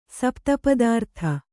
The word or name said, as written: ಸಪ್ತಪದಾರ್ಥ